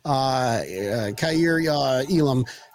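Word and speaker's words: Kaiir Elam: Yeah, I mean, Dane Jackson's playing lights out, and we got Kair Elam is going to did I say his name right? – Yeah. – So, okay. – Yeah, right.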